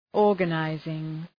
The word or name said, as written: organizing